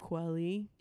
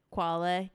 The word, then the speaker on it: Kweli: Talib Kweli. – Yeah. – Yeah. Like Kwale. They call him Kwa on the tracks.